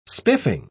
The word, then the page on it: spiffing: British English